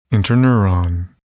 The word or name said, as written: interneuron